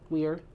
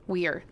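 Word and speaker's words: Weier: It's W-E-I-E-R. – Weir. – Weir. That's Weir. How you say it.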